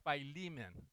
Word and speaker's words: Philemon: It's pronounced Philemon, okay? Not Philemon, okay? Just to let you know. It's Philemon, our beloved co-worker, and to our sister Apphia, and to our fellow soldier Archippus, and to the church that meets in your house.